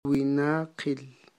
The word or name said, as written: winaqil